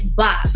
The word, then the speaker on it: BAS: Boss. The name is Boss. Don't forget it. Don't mispronounce it. It's Boss.